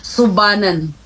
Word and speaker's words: Sobanin: Pero sa amin po sir, bilang mga tribo, ang tamang pagbigkas nun sir, Sobanin. – Sobanin. Parang ganoon. – Tama ba? – Sobanin. – Sobanin. – Oo, para labas sa ilong.